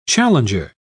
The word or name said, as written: challenger